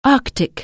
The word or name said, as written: arctic